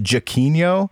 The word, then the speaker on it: Giacchino: It's pronounced Giacchino. But the way you spell it is G-I-A-C-C-H-I-N-O.